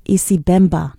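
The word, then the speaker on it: Icibemba: Today's New York Slice featured artist is Muriel Mwamba, a New York City-based Zambian artist who sings in several languages, including Isimba. I think I said that right. Isibemba. No, I didn't say it right.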